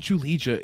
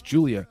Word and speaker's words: Julija: it's not Julija, it's Julia. But it looks like Julija Stolarenko based upon English pronunciation. It's pronounced, though, Julia Stolarenko, if you're using, I guess, her pronunciation.